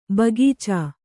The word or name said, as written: ಬಗೀಚೆ